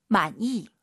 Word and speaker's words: 满意: 满意. – 많이, 많이, 니은 발음 들리면 안 돼요. 이응, 많이, 많이. 이렇게 발음하시기 바랍니다.